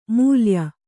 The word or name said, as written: ಮೇಲಾಯ